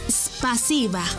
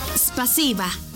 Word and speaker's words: Спасибо: En ruso, gracias se dice Спасибо. ¿Cómo? Спасибо. Una vez más. Спасибо. Y se escribe Спасибо. S P A S I B A. Спасибо.